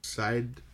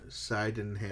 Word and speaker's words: Sydenham: So I'm guessing he went with the Elixir Coffee Roasters on, I have no idea how to say this street name, South Sydenham Street. – Sydenham. – Sydenham. Yeah. Side of ham, like a side of ham.